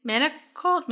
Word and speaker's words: manacled: How do you spell that? – M-A-N-A-C-L-E-D. – M-A-N-A-C-L-D? – E-D. – E-D. Manacled? – Manacled? Maybe. Well, maybe.